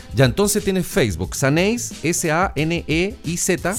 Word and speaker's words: Saneiz: Saneis, que se escribe, chiquillos, como les dijimos, S-A-N-E-I-Z. Saneis, que es Sandra Neira Zúñiga.